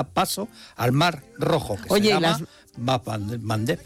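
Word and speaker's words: Bab el-Mandeb: Bab el Mandeb. – Bab el Mandeb, esa me la voy a apuntar. – Cuesta un poquito pronunciarlo, por Mandeb. Eso en Occidente se dice el Mandeb.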